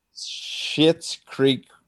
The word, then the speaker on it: Schitt's Creek: It's not technically a bad word, but it's going to sound like it's pronounced as a bad word. So it's S-C-H. – S-C-H. – Schitt's Creek Trivia.